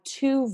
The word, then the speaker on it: Thie: And when they traced it back, originally the name was T-H-I-E. And in German, you don't like make a sound with an H. And then whenever you have two vowels by each other, like I-E, you pronounce the long vowel version of the second vowel. So you only would pronounce the T and the E. So it's just T.